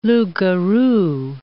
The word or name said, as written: loup-garou